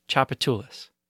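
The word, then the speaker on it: Tchoupitoulas: If you can pronounce Chapultepec, you'll sound like a local. So let's break that down. It's T-C-H-O-U-P-I-T-O-U-L-A-S, Chapultepec.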